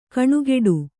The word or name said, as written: ಘನಘಟ್ಟಿ